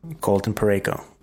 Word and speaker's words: Colton Parayko: Is it Parayko? – Colton Parayko. Learn the damn man's name and say it properly.